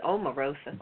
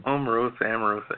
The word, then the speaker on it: Omarosa: But what happened with Amorosa, if I'm saying her name right, is it Amorosa? – I think it's Omarosa. – Omarosa.